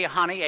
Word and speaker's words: Honey: Honey, H-O-N-E-Y. – I pronounce it Honey. P.J. Honey. Honey, yes, out of London. – H-O-N-E-Y, I thought was Honey.